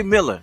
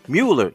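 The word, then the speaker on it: Muller: Jerry Miller, or you can pronounce his name, Jerry Mueller, came out with a book called Capitalism and the Small Hats.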